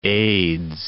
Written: aids

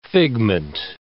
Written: figment

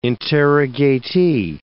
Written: interrogatee

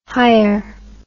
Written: higher

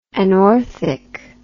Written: anorthic

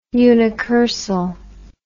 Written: unicursal

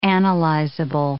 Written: analyzable

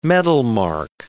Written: metalmark

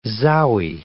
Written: zowie

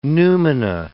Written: numina

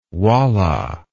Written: walla

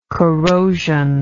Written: corrosional

